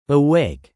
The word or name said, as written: awake